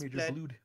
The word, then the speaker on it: Blud: Now, you would think either he was a normal human and spelt it B-L-O-O-D or B-L-U with the umlaut D, but no. – Mm-hmm. – This dude's dumb. He spelt it B-L-U-D. So it's Blood. Mm-hmm. So he's Commander Blood.